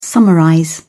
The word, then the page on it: summarize: British English